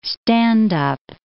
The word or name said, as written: stand-up